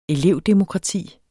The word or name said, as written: elevdemokrati